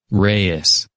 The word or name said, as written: Reyes